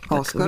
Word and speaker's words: Оскар: Оскар. – Винаги въркам ударението.